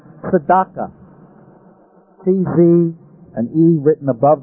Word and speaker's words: tzedakah: D-A-K-A-H. The reason the E is written above the line is because it's a half vowel. It's only there so you can pronounce T-Z-D. Otherwise, without a vowel, there's no way you could pronounce T-Z-D. Your mouth couldn't get around it. And so they stick in a half vowel to make it pronounceable. Tzedakah. Tzedakah. You pronounce it as if you're trying to say them without a vowel, and you barely hear the vowel.